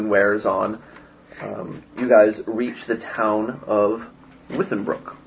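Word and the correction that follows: Weissbruk: Oh, sorry, Weissbrook. – How do you spell that? – Weiss is W-E-I-S-S, and then Brook is B-R-U-K. It's actually all just one word.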